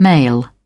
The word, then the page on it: male: British English